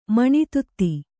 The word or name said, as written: ಮನೆದೊತ್ತು